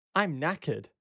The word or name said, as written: I'm knackered.